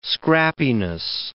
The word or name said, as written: scrappiness